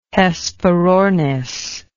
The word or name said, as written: hesperornis